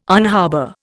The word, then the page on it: unharbour: Indian